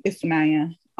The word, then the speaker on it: Ifunanya: It's N-W-A-N-O, and even the N-Y, and my name Ifunanya, is the N-Y is one letter in Igbo.